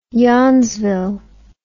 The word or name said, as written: yawnsville